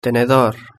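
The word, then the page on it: tenedor: Spanish (Spain)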